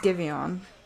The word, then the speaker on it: Giveon: It's Giveon.